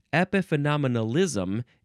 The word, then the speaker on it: epiphenomenalism: Epiphenomenalism. Epiphenomenalism. 8 syllables.